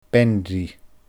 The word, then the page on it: bean-rì: Scottish Gaelic